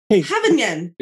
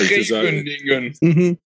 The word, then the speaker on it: Scheveningen: The weird thing about Scheveningen for you guys is that the S-C-H is pronounced Havingen. – Yes, it's right. He's right. – Havingen.